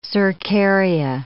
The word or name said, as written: cercaria